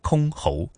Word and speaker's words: 箜篌: 原来读音叫。箜篌，呃，这是一个什么样的乐器啊？是否已经是随着这个时代的变迁？逐渐的有了很多新的演绎啊。 – 嗯，箜篌呢，这种其实它是被称为皇家之乐的一种古代的弹拨乐器，距今呢已经有两千多年的历史了。